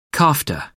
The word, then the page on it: kofta: American English